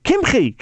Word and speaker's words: Kimchi: I said, David Kimchi. He says, oh, Kimchi. He was so upset with me for mispronouncing the name.